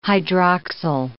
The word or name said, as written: hydroxyl